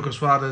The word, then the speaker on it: Quesada: And it's Quesada, right? Joe Quesada is the head of Quesada, yeah.